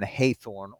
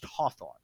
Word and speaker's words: Hawthorne: Now, I'd like to clarify that the name has always been pronounced Hawthorne. So it only looked in writing as if it might have been Hathorne or Hathorne. But it's always been pronounced Hawthorne.